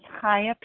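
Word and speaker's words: Chaya: My name is Kaya P. My name is spelled C-H-A-Y-A-P, and I live in Colorado.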